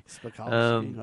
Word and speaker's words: Spakovsky: Well, Hans Spakovsky. – Spakovsky, okay.